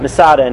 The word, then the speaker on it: Masada: Masada. And how do you say it in Hebrew? Matsada. Masada in English.